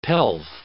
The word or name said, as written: pelf